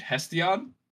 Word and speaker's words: Hesiod: a poet named Hesiod, I believe is actually how it's pronounced, and he was writing a poem or some kind of form of literature.